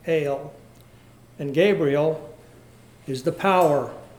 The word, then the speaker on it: El: In both names, we find that word, El, E-L. Brother Thomas says the correct pronunciation is El, which has the idea of power, might, or strength.